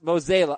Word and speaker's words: Mozeliak: It's pronounced Mozeliak.